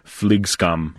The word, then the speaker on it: Flygskam: Do you want to try to pronounce it? – Yeah, I actually like that. Flygskam. – Yeah, there it is.